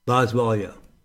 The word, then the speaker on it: Boswellia: Boswellia.